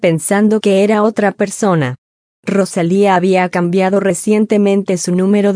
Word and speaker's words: Rosalía: Luego, a Fallon se le dio una lección sobre cómo rodar sus R para que pudiera pronunciar Rosalía como se pretendía. Ambos compartieron un apretón de manos para celebrar su habilidad para hacerlo en su primer intento. «Eso no está mal», dijo Rosalía antes de dar ejemplos de cómo la gente suele pronunciar mal su nombre. «He oído. Rosalinda, Rosalita, Rosalina».